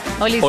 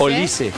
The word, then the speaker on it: Olise: Olise. – Olise. – Yo tengo con tilde en la I. – Con la I. Olise.